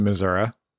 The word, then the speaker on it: Missouri: My father was born and raised in Missouri, and he called it Missouri.